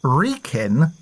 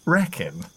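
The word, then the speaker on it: Wrekin: Apparently, in the Shropshire episode, it's pronounced Clun, not Clune, and Reekin, not Reckon.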